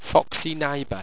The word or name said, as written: foxyniba